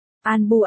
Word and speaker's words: ANBU: An Bu ám bộ, tiếng Nhật đọc là ANBU chữ An Bu dịch nghĩa.